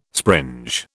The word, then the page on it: springe: British English